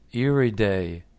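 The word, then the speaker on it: Iride: I ride." The exhibitor said, "It's pronounced Iriday, not I ride. Iriday is Italian for iris."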